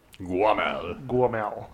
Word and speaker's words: Gwoemul: And it's like Guomul? – G-W-O-E-M-U-L. – Guomul, yeah.